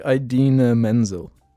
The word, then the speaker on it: Idina Menzel: From memory, the name is actually so different to what he said. – This is the name. It's like Idina Menzel.